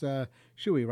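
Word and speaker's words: Schewe: It's S-C-H-E-W-E, and it's pronounced Chewy, right? – Chewy.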